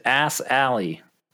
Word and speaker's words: Assalley: And his name is Ass Alley, like a road, like a road you drive on named after a butt.